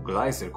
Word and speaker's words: Gleiser: Talvez um dos mais destacados ah, divulgadores científicos brasileiros seja o físico e astrônomo Marcelo Gleiser, ou Gleiser como alguns pronunciam.